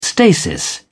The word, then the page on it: stasis: British English